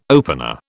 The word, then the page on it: opener: British English